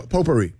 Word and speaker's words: potpourri: A silent T in there. – P-O-T. – P-O-T. – What? A T? – P-O-T-P-O-U-R-R-I. – It's not potpourri. – It's silent T.